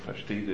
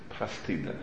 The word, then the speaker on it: pashtida: Pashtida is not pronounced pashtida, it's pronounced pastida, and it's pasta.